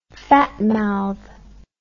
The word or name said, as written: fatmouth